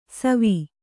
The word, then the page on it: ಸವಿ: savi